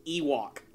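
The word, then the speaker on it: Yhwach: The first one, literally, his name is pronounced Ewok, like a freaking furry creature from the planet of Endor.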